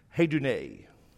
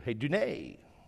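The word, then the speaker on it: hedune (ἡδονή): Hedone. If you want to say it like the professors do, hedune.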